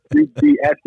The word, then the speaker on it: UBS: And it's funny because some people, even the arena announcers, they're pronouncing it USB Arena on TV sometimes, especially in USB. I'm like, no, but UBS, they paid a lot of money for that arena.